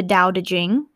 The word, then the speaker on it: Tao Te Ching: You can read the Tao Te Ching. It's spelled T-A-O-T-E. Or it's D-A-O-D-E and then Jing.